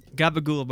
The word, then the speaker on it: gabagool: Gabagool, by the way, is capicola. But that's how they say it.